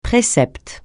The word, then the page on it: précepte: francais